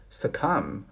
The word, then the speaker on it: succumb: 第三个单词比较重要是 succumb 啊，不及物动词。注意这个最后的 b 是不发音的。